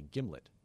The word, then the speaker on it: gimlet: You'd say give me a gimlet. So a giblet. It's a hard G.